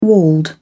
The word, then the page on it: walled: British English